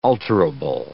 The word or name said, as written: alterable